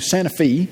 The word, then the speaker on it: Santa Fe: When I transferred out to Santa Fe, you pronounce it Santa Fe if you're from there. If you don't, it looks like it's supposed to be pronounced Santa Fe. But it's not. It's Santa Fe.